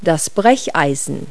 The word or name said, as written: crowbar